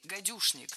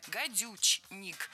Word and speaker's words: гадючник: Змеюшник и гадюшник. Что интересно, оба слова в словарях зафиксированы. Такие существительные есть в русском языке. Но хочу сделать небольшое предостережение. Гадюшник пишется с ч, гадючник, но произносится с ш, гадюшник.